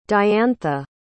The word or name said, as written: diantha